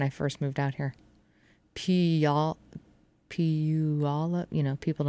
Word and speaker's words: Puyallup: I used to say pull on up.